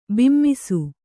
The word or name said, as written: ಬಿಮ್ಮಿಸು